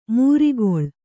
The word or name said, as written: ಮೂರಿಗೊಳ್